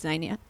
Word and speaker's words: xenia: and xenia, I believe it is, which is strangers. Is it xenia? No, it's... Anyway, yeah. Xenia, I think, is perhaps a more correct pronunciation.